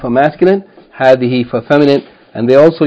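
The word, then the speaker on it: hadhihi (هذه): And hadhihi is pronounced as though the first syllable is long, hadhihi, in the same way as hadha is pronounced hadha, as though there's an aleph, even though it is not written.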